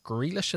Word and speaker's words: Grealish: You add to it, Jack Grealish, is that how you say it, his name? – Yep.